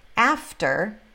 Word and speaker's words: after: I felt much better after. After. Tem som de T mesmo, né? Não é after, é after.